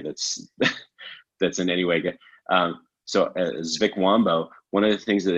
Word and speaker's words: Zvikwambo: There is their version of a goblin is called I'm gonna butcher this, a Zvikwambo. – And a Zvikwambo. – Yeah, Zvikwambo. Z-V-I-K-W-A-M-B-O. Zvikwambo.